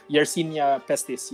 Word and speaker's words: Yersinia pestis: Yersinia pestis, is this right? – Yersinia pestis, yes.